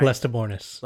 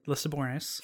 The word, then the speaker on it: Lestibournes: Lestibournes. Lester Born Lestibournes, now known as Spook.